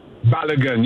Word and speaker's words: Balogun: There's a young man, I believe you pronounce his name, Folarin Balogun. Did I say that right, or am I messing up that name? – Balogun. You got it right, Myron.